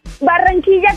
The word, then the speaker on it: Barranquilla: Barranquilla, Colombia. – Oh, my gosh. – Try to roll your R like that. – Wow. – Wait, say it again. – Barranquilla, Colombia. – Barranquilla.